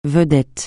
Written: vedette

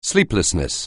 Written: sleeplessness

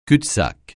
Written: cul-de-sac

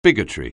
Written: bigotry

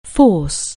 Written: force

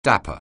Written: dapper

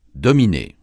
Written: dominer

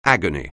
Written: agony

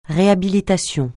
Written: réhabilitation

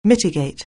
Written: mitigate

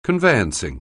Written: conveyancing

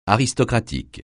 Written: aristocratique

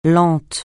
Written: lente

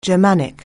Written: Germanic

